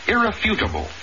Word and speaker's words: irrefutable: The word irrefutable. Accent on the third syllable, not the second, Johnny.